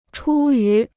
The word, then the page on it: 出于: chū yú